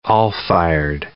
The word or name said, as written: all-fired